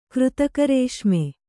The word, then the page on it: ಕೃತಕರೇಷ್ಮೆ: křtaka rēṣme